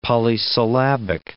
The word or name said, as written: polysyllabic